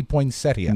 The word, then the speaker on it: poinsettia: It's spelled P-O-I-N. – Sleeping 2 hours at a time. What? – P-O-I-N-S-E-T-T-I-A, so it would be poinsettia. – No, poinsettia. – The I is silent.